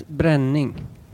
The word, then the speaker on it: Brenning: Johannes Brenning. – Brenning. Do you pronounce it like that? – Yeah.